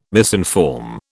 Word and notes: misinform: British English

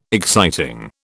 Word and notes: exciting: British English

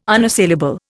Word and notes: unassailable: Indian

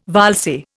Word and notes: valse: Indian

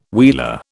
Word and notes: wheeler: British English

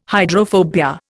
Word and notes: hydrophobia: Indian

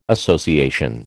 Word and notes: association: American English

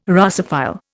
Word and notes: Russophile: Indian

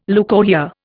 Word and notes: leucorrhoea: Indian

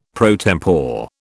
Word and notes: pro tempore: British English

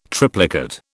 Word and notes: triplicate: British English

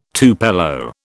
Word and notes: tupelo: British English

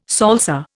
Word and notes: salsa: Indian

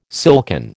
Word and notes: silken: American English

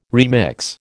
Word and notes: remix: American English

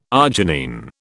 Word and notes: arginine: British English

